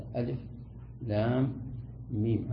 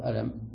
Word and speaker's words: ألف لام ميم: ألف لام ميم، على أنها حروف مقطعة ليست حروف متصلة منفصلة، ولا تقول ألم وإنما تقول ألف لام ميم، هذا نطقها من حيث الأداء والنطق.